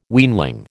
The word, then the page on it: weanling: American English